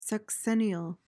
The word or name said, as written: sexennial